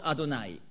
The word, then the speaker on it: Adonai: So spricht man es aus, Adonai.